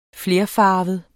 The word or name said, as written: flerfarvet